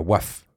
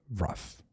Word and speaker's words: Wrath: Et le morceau en question, c'était Wrath, et pas Waf, Wrath. Encore, je le prononce très mal, je pense.